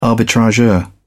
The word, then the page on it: arbitrageur: British English